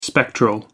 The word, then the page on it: spectral: American English